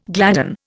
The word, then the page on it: gladden: Indian